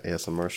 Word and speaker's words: ASMR: You're not saying it right. – My asthma. – You're saying AMSR.